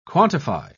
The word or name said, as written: quantify